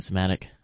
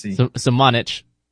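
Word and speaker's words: Sochan: Somatic, or Šamanić, I want to keep saying Somatic. I don't want to hit myself. – No hard C. – Šamanić, he's probably my favorite out of the fundamentals that this dude has.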